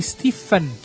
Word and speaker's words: Stephen: Stephen King é meu autor favorito e gostei muito de ouvir vocês falarem dele, exceto quando falavam o nome. A pronúncia é Stephen que eu tô falando aqui.